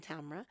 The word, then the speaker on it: Tamra: Actually, my grandmother named me Tamra. Rhymes with camera. Okay. So all around the world, Muslims are calling me things like tomorrow and Tamara and things like this. But I'm working on helping them pronounce my name correctly. It's Tamra. Yeah.